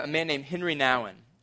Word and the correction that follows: Henri Nouwen: I, I, think actually his, his name is pronounced Henri Nouwen.